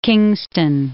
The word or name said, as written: Kingston upon Hull